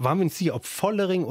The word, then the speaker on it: Vollering: Vollering zwischen F und V. Vollering.